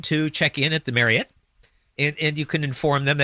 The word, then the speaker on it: Marriott: Marriott. – Ending in like O-T-T, but that's not the right pronunciation, dude. It's supposed to be Marriott. – Marriott. – Like chariot. So it's not Marriott, it's Marriott.